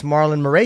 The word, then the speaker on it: Marlon Moraes: And Rafaela Sunsau against Marlon Moraes.